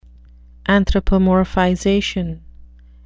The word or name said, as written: anthropomorphization